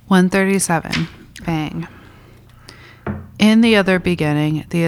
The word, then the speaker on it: Beyng: We're on section 137 of Contributions to Philosophy of the Event by Martin Heidegger, and the section is called Bang, B-E-Y-N-G, which is his alternative spelling of being, but we pronounce it bang to just differentiate it.